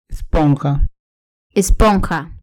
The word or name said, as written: esponja